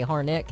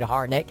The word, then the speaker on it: Harnett: I put the stress on the second syllable. He's like, that's not how you say Harnett. And he said, Harnett, say helmet. I said, helmet. He said, now say Harnett.